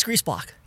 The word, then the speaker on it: Griesbach: You talk specifically about the area that you're representing, which is Griesbach. Was that, I hope I've got the pronunciation right. Is that right? – It's Griesbach. – Griesbach. – My favorite composer, Bach.